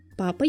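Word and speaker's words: papa: «Папа» является заимствованием из французского языка и появилось не так уж давно, около 200 лет назад. Происходит от французского «папа» – ударение на последний слог.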